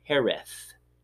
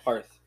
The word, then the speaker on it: Hereth: H-E-R-E-T-H. – Hereth, yeah. – Hereth. Kind of like Harris, but with a lisp. Hereth. – So there we go. Mike Tyson was saying it. – Yeah. – Hereth.